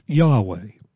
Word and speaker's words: Yahweh: In Hebrew, its exact pronunciation has been lost, because the Jewish people regarded it as so sacred to say that they even disguised its vowel pointings to prevent it. But as best as scholars can determine, it was the covenant name of God, "he is" or "he who is," pronounced Yahweh.